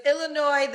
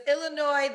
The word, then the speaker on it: Illinois: Listen, I get all words wrong all the time, but it's Illinois, the S is silent. – Yes. – You are so dumb. You are really dumb, for real. – But it's Illinois, the S is silent.